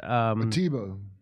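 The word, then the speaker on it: Ateba: DeBeta guy, right? So, Simon DeBeta is a African journalist. – Atiba. – Is that how you say his name? Yeah. Okay, I'm butchering. Okay, I'm butchering his name.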